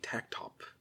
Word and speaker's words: Tactop: Do you pronounce the T or is it a silent T? – You pronounce the T, I believe. – Tactop.